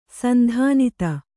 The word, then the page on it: ಸಂಧಾನಿತ: sandhānita